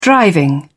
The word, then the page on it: driving: British English